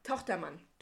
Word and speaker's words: Tochtermann: Tochtermann, ja, ist wie Richter Richter. Ja, Tochtermann. Tochtermann. Er heißt so.